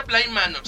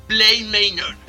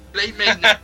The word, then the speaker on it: Bly Manor: No se dice Bly Manor, se dice Bly Manor. – Ok, Bly Manor.